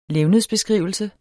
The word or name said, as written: levnedsbeskrivelse